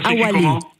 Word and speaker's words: awalé: La apostrophe awélé. Et ça s'écrit awalé. Awalé. A-W-A-L-E. C'est quoi ? – Awalé.